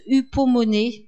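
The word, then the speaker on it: hypomone (ὑπομονή): Le mot endurons ici, c'est un mot grec qui se prononce hypomone.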